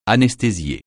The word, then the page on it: anesthésier: francais